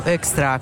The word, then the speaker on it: Hoekstra: Esattamente, io sono stata presente quando l'ha sollevato proprio al commissario Hoekstra, con questo cognome difficile, ma insomma noi abbiamo deciso che lo pronunciamo così.